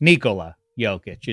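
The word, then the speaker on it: Nikola Jokić: Nikola Jokic is the go-to pronunciation of that Giants name there.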